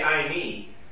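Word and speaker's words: I me (εἰμί): You could just say I me, which in English letters we would spell it E-I-M-I, in case you're wondering.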